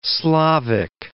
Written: Slavic

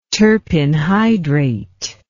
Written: terpin hydrate

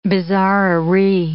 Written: bizarrerie

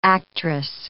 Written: actress

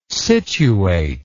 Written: scituate

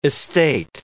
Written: estate

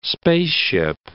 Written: spaceship

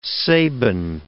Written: Sabin

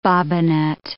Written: bobbinet

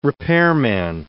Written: repairman

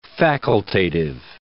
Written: facultative

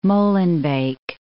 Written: Molenbeek

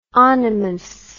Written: onymous

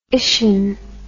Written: ition

